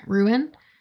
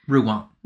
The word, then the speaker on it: Rouen: Am I saying that right? Rouen? – Rouen. – Rouen. My bad.